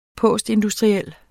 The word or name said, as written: postindustriel